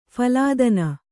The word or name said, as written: ಫಲಾದನ